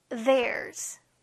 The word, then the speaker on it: theirs: theirs. th 조심. theirs. their 하고 끝내지 않고 s 발음까지 챙겨주세요.